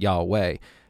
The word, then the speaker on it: Yahweh: which we sometimes, or predominantly is pronounced Yahweh, but the name was sacred to Jews.